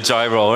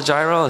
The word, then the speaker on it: gyro: I eat hot dogs, I drink coffee, and I eat a gyro, right? And I was like, it's not called a gyro? It's like, no, it's called gyro. The G is silent.